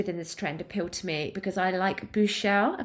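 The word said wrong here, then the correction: bouclé: Am I saying that right, Gemma? – Boucle upholstery, which is that kind of teddy bear fabric, or fine knits or soft crinkly linens.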